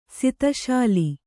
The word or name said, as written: ಸಿತಶಾಲಿ